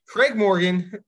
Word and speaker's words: Morgan: So Craig Morning, wow, I really butchered that last name. Craig Morgan from PHNX Coyotes, really, really good journalist.